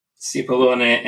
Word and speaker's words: Cipollone: Cipollone, and everybody call me Frank for friends.